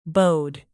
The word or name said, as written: bode